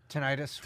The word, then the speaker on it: tinnitus: Tinnitus.